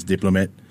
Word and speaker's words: DPLMT: So uh, we created the collective, it's pronounced as Diplomat. We just removed all the vowels, make it look cool.